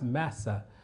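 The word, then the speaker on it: massa (מַשָּׂא): This, this the, the word burden is, is, is pronounced massa in, in Hebrew.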